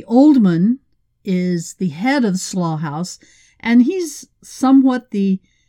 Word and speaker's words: Slough: It's spelled S-L-O-U-G-H, but pronounced slough, close to slow.